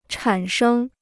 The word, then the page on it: 产生: chǎn shēng